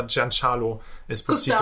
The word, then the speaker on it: Giancarlo Esposito: Wie er jetzt ausgesprochen Also ich meine, er hat mal auf Twitter hat er seinen Namen ausgesprochen, irgendwie in einem Video und da hat er Giancarlo Esposito.